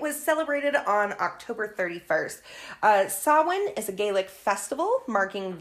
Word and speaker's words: Samhain: It is spelled Samhain. – Yes. – So, and some people do pronounce it that way. Okay. – But that's not correct. It is Samhain.